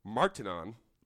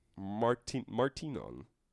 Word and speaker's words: Martinon: The main character, Martinon, like Martin, but with an O-N. Martinon. – What? – Martinon. It's just spelled like Martin. An O-N, another O, add an O-N at the end.